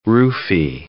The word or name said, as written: roofie